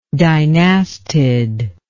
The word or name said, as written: dynastid